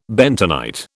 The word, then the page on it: bentonite: British English